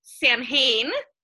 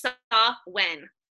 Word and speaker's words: Samhain: It's spelled Samhain. It looks like Samhain, but it's Samhain.